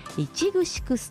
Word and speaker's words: 池城: ツアーのハイライトは地域の胃袋を支えるいちぐしくストア、これ池城ストアと書いていちぐしくと言います。